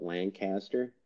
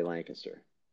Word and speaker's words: Lancaster: That is how you pronounce it. People from out of town or from out of the area call it Lancaster, but it's really Lancaster is what you would hear locally.